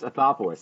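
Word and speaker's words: Anthopoulos: That would be Alex Athopoulos. I said it right that time. I did not add an R. It's Alex Athopoulos.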